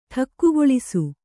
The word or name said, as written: ಠಕ್ಕುಗೊಳಿಸು